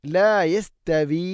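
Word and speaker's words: لا يستوي: അടുത്ത ആയത്ത്, ലാ യസ്തവി അസ്ഹാബുൻ നാർ. ലാ യസ്തവി. യസ്തവി തഅ്നെ കനപ്പിച്ചു ഉച്ചരിക്കരുത്, ലൈറ്റായിട്ട് ഉച്ചരിക്കണം. ലാ യസ്തവി. ലാ യസ്തവി അല്ല, ലാ യസ്തവി.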